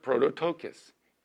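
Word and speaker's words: prototokos (πρωτότοκος): Pro, P-R-O, to, T-O, and then to, T-O, K-O-S. It's pronounced prototokos. P-R-O-T-O-T-O-K-O-S.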